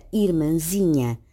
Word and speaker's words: irmãzinha: como se observa também na palavra irmãzinha, na qual a sílaba tónica é zi e não mã.